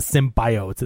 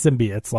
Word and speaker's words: symbiotes: But like, No, it's not symbiotes. It's symbiotes. Like, yeah, we've heard it pronounced that way on the shows.